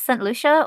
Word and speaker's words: Saint Lucia: If you want to say it in Spanish, it is Santa Lucia, but we don't speak Spanish. So it's Saint Lucia, or we call it Saint Lucie.